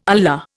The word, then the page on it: Allah: Indian